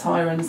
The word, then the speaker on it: Hirons: No, it's Hirons.